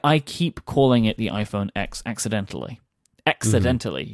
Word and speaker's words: iPhone X: But no, it is the iPhone X, and I will call it the iPhone X, and I will endeavor to call it the iPhone X for as long as the iPhone X exists, because that's the correct pronunciation of the product.